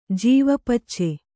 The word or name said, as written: ಜೀವಪಚ್ಚೆ